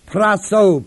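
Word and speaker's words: prasso: That they which commit, the word commit is the verb praso, P-R-A-S-S, long O, which means that they which continue to practice such things are worthy of what?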